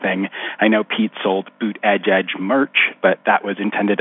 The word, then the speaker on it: Buttigieg: So you guys, it's Buttigieg. Buttigieg, for the love of God, please, Buttigieg.